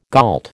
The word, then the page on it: gault: American English